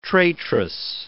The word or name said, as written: traitress